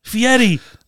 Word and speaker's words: Fieri: Fieri, don't mispronounce his name.